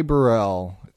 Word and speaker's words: Burrell: It rhymes with Parnell. That would be Ty Burrell.